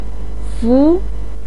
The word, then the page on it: fu2: pinyin